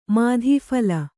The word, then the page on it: ಮಾದೂಫಲ: Kannada